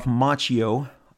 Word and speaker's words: Macchio: I believe Ralph Macchio, yes, pronounced like the actor,